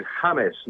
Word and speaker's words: James: But we pronounce it James, not James, because it's James Rodriguez.